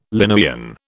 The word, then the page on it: Linnaean: British English